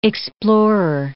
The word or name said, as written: explorer